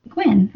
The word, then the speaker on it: Guinn: And it's by Jeff Gwynn. I'm hoping I'm saying that right. Guinn. – Sounds like when. – Gwynn, right?